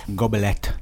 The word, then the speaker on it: gobelet: Emma is also saying it's a hard G in French. – It's a hard G. – It sure is. – Gobelet.